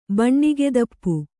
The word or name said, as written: ಬಣ್ಣಿಗೆದಪ್ಪು